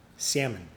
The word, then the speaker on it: salmon: Salmon. Salmon. say it with a silent L.